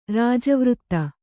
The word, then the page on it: ರಾಜಾವರ್ತ: rājāvarta